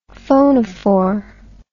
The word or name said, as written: phonophore